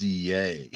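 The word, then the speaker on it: Ye: It's actually pronounced Yay.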